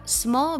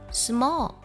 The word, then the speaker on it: small: have small beginnings，这里有个难度的单词 small，不少同学呢容易加儿化音，读作 small，要注意不能在我们的舌侧音这里去打卷，不能卷舌 small，舌尖呢抵在上齿龈后侧就好了。